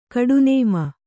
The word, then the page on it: ಗೂಟನಾಮ: gūṭa nāma